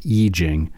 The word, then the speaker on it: I Ching: Oh yeah, I Ching. It looks like the word I space Ching, C-H-I-N-G. It's pronounced I Ching.